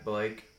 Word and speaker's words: Blake: My name is Zach Blake. That's with an H. Z-A-C-H. And then my last name is spelled B-L-A-K-E.